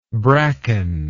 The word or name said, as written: brackened